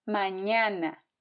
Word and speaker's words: mañana: It's as in the word onion, but more nasal. For example, mañana. Mañana.